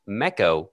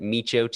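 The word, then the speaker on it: Meco: That's Mecco, which is just echo with an M in front of it. How easy is that? Mecco Radio, you can pronounce it Micho too if you want.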